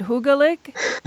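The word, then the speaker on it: hyggelig: But actually, you're going to have to pronounce it hygge-lik.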